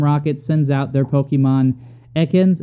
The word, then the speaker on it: Ekans: Yes, so it is pronounced Ekans.